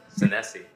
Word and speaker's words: Senesi: How do you say your last name? Sensei? – Senesi.